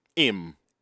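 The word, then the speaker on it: 'im: The first one, im. Him Yes. You write it apostrophe I am. Mm-hmm. because we don't pronounce H.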